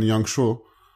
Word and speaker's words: Yangshuo: And actually nearby-ish, I mean, I went to visit Guilin and Yangshuo.